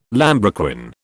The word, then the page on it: lambrequin: British English